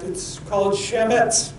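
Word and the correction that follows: chametz: or pronounced actually hemetz. And this instruction to remove the yeast or hametz is taken quite seriously.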